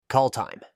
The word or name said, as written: Call time